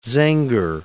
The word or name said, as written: Zenger